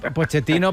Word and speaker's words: Pochettino: Home, Pochettino, Pochettino, però si el seu cognom és italià o descens d'italians, sí. – la pronunciació correcta, però clar, els argentins també són especialets i no, jo soc Pochettino, no Pochettino, vale, ja està.